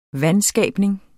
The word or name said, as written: VANSKABNING